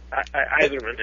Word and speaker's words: Yzerman: The thing about, was it, I don't know how to pronounce the name, Yzerman? Iserman? – Iserman, yeah.